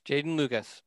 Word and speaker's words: Jeadyn Lukus: Let's move on to the five-star, Jeadyn Lukus. Am I pronouncing that right? Because it's spelled weird. – Yes. – Okay. – Yes, Jeadyn Lukus. – It's Jeadyn.